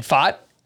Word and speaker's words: Pfaadt: And that's an annoying pick because I wanted to draft Brendan Faught, who is a pitching prospect with the Diamondbacks. That name is spelled P-F-A-A-D-T if you're looking him up.